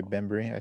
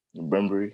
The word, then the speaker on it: Bembry: Burberry, whatever his name is. I think I pronounced that wrong, but he's just a decent defender. – DeAndre Bembry, I think. – Bembry, yeah.